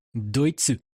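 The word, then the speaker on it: Doitsu (ドイツ): Doitsu.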